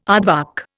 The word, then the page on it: aardvark: Indian